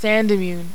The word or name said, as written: Sandimmune